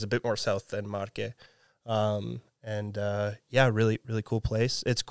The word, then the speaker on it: Marche: So my grandparents are from the provinces are Marche and Abruzzo, which are they're kind of neighboring provinces.